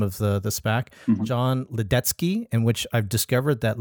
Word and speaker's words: Ledecky: And so when I saw that last name, my wife is Czech. So it's like easy for me to then see his name and say Ledecky. That's the way you pronounce it.